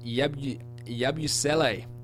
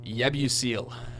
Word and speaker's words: Yabusele: Gershon Yabusele, who, as the PA announcer called him until he was corrected at the end of the first quarter, Yabusele. Which are, yeah, pretty humorous. Learn to pronounce the names. It's clearly not Yabusele.